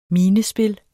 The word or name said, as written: minespil